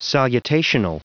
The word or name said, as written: salutational